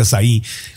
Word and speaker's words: açaí: Oye, el açaí que se puso, se escribe A-C-A-I, pero es de Brasil, entonces se pronuncia açaí, que estuvo muy de moda.